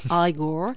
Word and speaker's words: Igor: Bon, Marty Feldman, qui quand même interprète un Igor, attention à la prononciation cette fois, il faut dire Igor, donc un Igor plus fou que jamais.